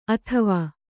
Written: ಅತ್ವ

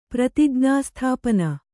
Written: ಪ್ರತಿಜ್ಞಾಸ್ಥಾಪನ

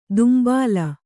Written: ದುಂಬಾಲ

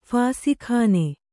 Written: ಫಾಸಿಖಾನೆ

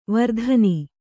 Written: ವೀರತನ

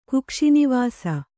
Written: ಕುಕ್ಷಿನಿವಾಸಿ

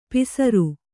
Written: ಪಿಸರು

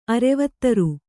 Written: ಅರೆವತ್ತರು

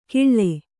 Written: ಕಿಳ್ಳೆ

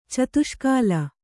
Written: ಚತುಷ್ಕಾಲ

